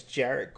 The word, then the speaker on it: Jarrett: My name is Jarrett Courtney. Rhymes with carrot, parrot, ferret.